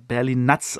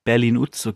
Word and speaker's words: Berlin Nuts: Und dann hatte ich ja noch äh, den Mackie da und äh, Mackie hat sich auch gemeldet und meinte, äh, du, das spricht man äh, berlin Nuts aus. Also ich habe immer Berlin-Utz gesagt, glaube ich, oder Berlin-Utz, Berlin-Utz, aber Berlin-Nuts, Berlin, Berlin also es ist englisch ausgesprochen und Nuts halt wie, wie Nüsse.